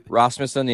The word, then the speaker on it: Rasmussen: Is that how you say his name? – Rasmussen, yeah.